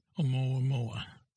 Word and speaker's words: Oumuamua: Now, I'm not sure of the pronunciation, so please, Oumuamua? – Yeah, Oumuamua.